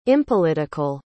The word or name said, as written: impolitical